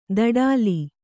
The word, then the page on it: ದಡಾಲಿ: daḍāli